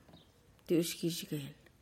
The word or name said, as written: tiuxgixgehl